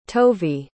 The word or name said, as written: tovi